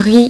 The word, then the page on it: riz: French